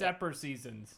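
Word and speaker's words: Ephesians: I wasn't raised Catholic, so how do you say this? Epper seasons? EP. – It's Epper seasons.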